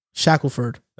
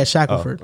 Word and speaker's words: Shackelford: Shackelford or Shakefield? – Shackelford. – That's his name? That's how you pronounce it? – Yeah, Shackelford.